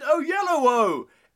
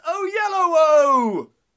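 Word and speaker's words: Oyelowo: But I say, less YOLO, more David Olilobo. It's God. David. So I mangled his pronunciation. – No, that was coming out right. – But I say, less YOLO, more David O'Yellowo. It's David O'Yellowo.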